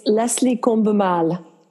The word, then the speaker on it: Leslie Combemale: It's Leslie Combemale, and also I'm known as Cinema Siren, and I created Women Rocking Hollywood.